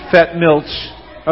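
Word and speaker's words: Fettmilch: Now, again, the correct pronunciation is probably Fetmilch, but since the ch doesn't come off so good, and it sounds really, you know, it just sounds right with Fetmilch, so I'm using Fetmilch.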